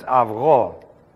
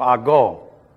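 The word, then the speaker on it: avgo (αυγό): It's one of the first words we try to get people who speak English first to try to pronounce is the word egg. And in Greek, it's avgo. Okay? And they go ago. No, ago.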